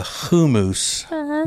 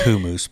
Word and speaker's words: hummus: It's really pronounced kumus, but I know most people don't pronounce it that way, so I won't.